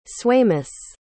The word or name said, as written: swaimous